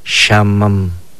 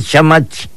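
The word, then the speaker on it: xaman: Decir norte, decimos shamam, shamam, escúchelo. Y por ahí me dicen shamach, shamach, no, shamach viene siendo comal.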